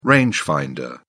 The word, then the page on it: rangefinder: British English